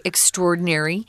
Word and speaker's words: extraordinary: Now, this word extraordinary, I sometimes hear people pronounce it extraordinary. Um, it isn't incorrect, but uh, typically we say extraordinary. We say those two words together. So, really unusual, very special.